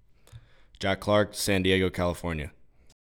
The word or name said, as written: Jack Clark